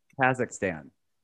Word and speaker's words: Kazakhstan: It's Kakistan. – Send me the information. – Kazakhstan.